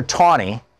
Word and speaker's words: Taney: And in that case, Roger Taney, it's spelled like it's Tanny, T-A-N-N-E-Y, but it was pronounced Taney.